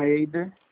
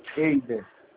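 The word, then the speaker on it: Heyder: Eider. – Eizer, é isso? – Isso. – É, Eizer, como é que se escreve Eizer? – H-E-Y-D de dado é R. – Ah, Z? – Não, H-E-Y-D de dado é R.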